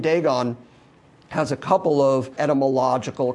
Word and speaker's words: Dagon: So sometimes Dagon is D-A-G-O-N, sometimes it's D-A-G-A-N, sometimes it's pronounced Dagon, Dagon.